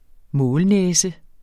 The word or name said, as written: målnæse